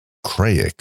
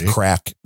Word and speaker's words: craic: The first phrase is, what's the Craix? – C-R-A-I-C. See, now you've just outed yourself as not really Irish. – Oh, really? – because that is crack. That is how they spell it in Ireland.